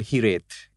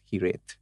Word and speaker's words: Hiraeth: It's called Hiret. Spelled differently. It's spelled as H-I-R-A-E-T-H, mm-hmm. but it's pronounced as Hiret.